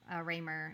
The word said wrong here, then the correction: Reiner: is it Raymer? How do you say his name? – Who? – The producer. – Reiner.